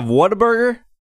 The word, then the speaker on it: Whataburger: And is it pronounced Whataburger? – What-a-burger? – Yes, not Whataburger.